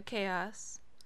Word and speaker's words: K-Os: This one being by Chaos. And no, it is not spelled C-H-A-O-S. It's spelled K-O-S.